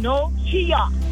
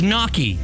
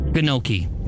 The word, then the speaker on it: gnocchi: Kenochia. – It's knocky. – What? – It's knocky. The G is silent. Like the word, like the bug gnat. The G is silent. Gnat. Gnocchi.